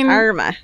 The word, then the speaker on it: Armagh: Armagh.